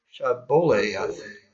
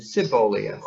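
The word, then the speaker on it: Shibboleth: If he said, Nay, then said they unto him, Say now, Shaboleth. And he said, Siboleth. For he could not frame to pronounce it right.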